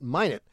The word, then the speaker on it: Minot: Maybe the North Dakotans don't like French, and they're just trying to get them ticked off, and they pronounce it Minot.